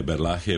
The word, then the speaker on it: Berlage: Znači, ja, to je, ne, Berlache je pravi izgovor na holandskom, tako da se žena ima pravo.